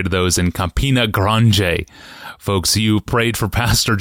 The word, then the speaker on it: Grande: Well, it's spelled Grande, G-R-A-N-D-E. – Yeah. – And and they said, oh, that's fine. You can say it that way. But everybody who said it in Brazil said Grande.